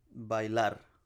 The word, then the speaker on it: bailar: Dance is baila, right? – Bailar.